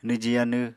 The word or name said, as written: nwji yanw